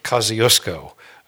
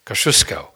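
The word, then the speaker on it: Kościuszko: Well, we say Kosciuszko. Oprah Winfrey, who was born in Kosciuszko, Mississippi, probably pronounces it, but in Poland we say Kosciuszko.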